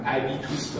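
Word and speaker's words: habitus: بعد معتقد در اینجا چیزی که خیلی مهمه همونی که عرض کردم ابیتوس به تعبیر تلفظ فرانسوی، چیزی که در طی اون جایی که آدم رشد پیدا میکنه این باعث میشه بیاد تو کجا منتها.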